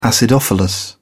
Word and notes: acidophilus: British English

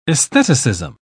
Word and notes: aestheticism: British English

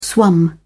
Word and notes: swum: British English

